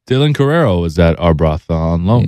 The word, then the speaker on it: Arbroath: How do you say Arbroath? – Arbroath. Yeah, Arbroath.